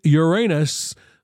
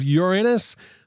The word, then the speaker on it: Uranus: According to some astronomers, we have been pronouncing Uranus, the planet, wrong our entire lives. Instead of Uranus, the planet is supposedly pronounced Uranus. Uranus, okay? N-U-S-S, which to me is not nearly as fun as saying it the other way.